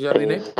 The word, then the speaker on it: Jardine: Mm, yo creo que el mejor entrenador para mí sería Jardine o Jardín, no sé cómo se pronuncia. – Sí, Jardine, del América.